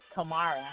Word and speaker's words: Camara: My last name is Kamara with a C.